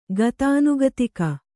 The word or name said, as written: ಗತಾನುಗತಿಕ